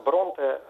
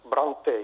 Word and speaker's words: Бронте: Ну и если вот есть англизм акцентный, если вот, наверное, и галлицизм есть, вот в частности, может быть, можно отнести фамилию английских писательниц Бронте, вот долгое время очень произносилось как Бранте, видимо, на французский манер.